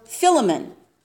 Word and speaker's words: Philemon: Paul tasked these two men to be the bearers of his letter to the Colossians and also a letter to a man named Philemon, or Philemon, as some pronounce it.